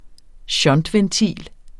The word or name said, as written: shuntventil